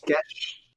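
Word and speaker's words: Geas: Uh, how do you say it? Geish? Gesh? Gesh. Gesh.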